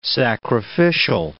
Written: sacrificial

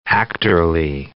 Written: actorly

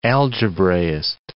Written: algebraist

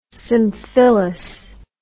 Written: symphyllous

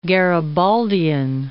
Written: Garibaldian

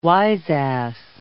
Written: wiseass

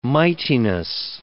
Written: mightiness

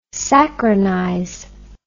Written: saccharinize

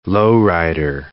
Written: lowrider

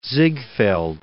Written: Ziegfeld

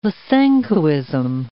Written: Lysenkoism

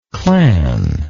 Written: clanship

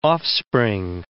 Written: offspring

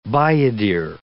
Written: bayadere

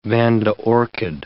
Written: vanda orchid

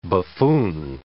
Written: buffoon

